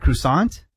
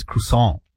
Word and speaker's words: croissant: Is it croissant? – Croissant. – Oh. – But that's not the proper pronunciation.